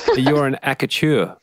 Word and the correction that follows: accoucheur: Yes, an accoucheur, that's correct. – That's how you say it.